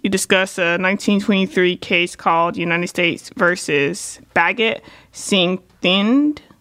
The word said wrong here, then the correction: Bhagat Singh Thind: Am I saying that correctly? I want to make sure that I said it properly. – I think it's probably Bhagat Singh Thind. – Bhagat Singh Thind. But that's okay.